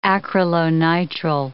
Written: acrylonitrile

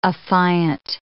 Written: affiant

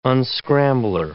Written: unscrambler

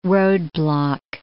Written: roadblock

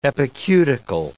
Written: epicuticle

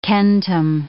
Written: centum

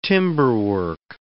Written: timberwork

